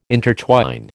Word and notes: intertwine: American English

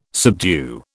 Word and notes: subdue: British English

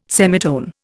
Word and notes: semitone: Indian

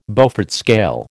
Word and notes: Beaufort scale: American English

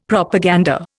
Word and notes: Propaganda: Indian

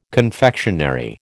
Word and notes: confectionery: American English

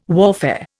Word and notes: warfare: Indian

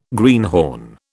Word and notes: greenhorn: British English

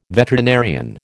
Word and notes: veterinarian: American English

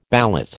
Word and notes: ballot: American English

